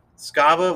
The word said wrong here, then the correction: Strava: what is your thing? – Strava. Don't you mispronounce it again.